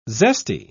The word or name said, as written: zesty